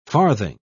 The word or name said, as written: farthing